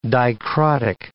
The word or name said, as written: dicrotic